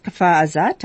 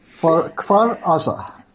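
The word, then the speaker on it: Kfar Aza: You went to the kibbutz Kfar Azat. I don't know if that's how you pronounce it. – Kfar Azar.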